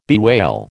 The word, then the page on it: bewail: American English